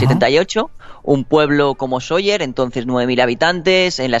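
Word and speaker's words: Sóller: Soller, con el acento en la primera O.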